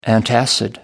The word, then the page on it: antacid: American English